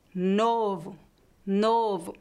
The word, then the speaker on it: novo: O primeiro o está em uma sílaba tônica, forte, por isso ele é pronunciado de forma forte. Novo, novo. O segundo o está em uma sílaba fraca no final da palavra, por isso ele é pronunciado como um u fraco.